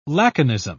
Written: laconism

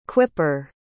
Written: quipper

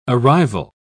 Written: arrival

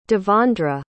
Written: devondra

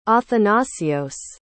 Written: athanasios

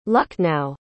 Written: lucknow

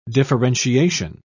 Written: differentiation

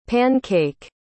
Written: pan-cake

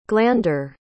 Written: glander

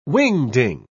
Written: wingding

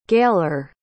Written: Gailer

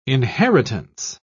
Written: inheritance

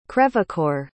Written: crèvecoeur

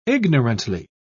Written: ignorantly

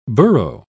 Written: burro

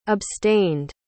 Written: abstained

